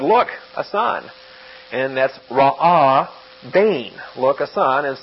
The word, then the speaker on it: ra'ah: It's not really a letter that you pronounce, but it's a closure of the throat. So like when you say honest, we have ra-ah with that little break.